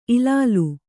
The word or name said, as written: ಇಲಾಲಿ